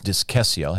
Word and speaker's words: discessio: I think I said it was pronounced discessio. I actually went and looked up some tools, pronunciation tools, and heard somebody actually say it in Latin, and it's discessio, has like a K sound, discessio.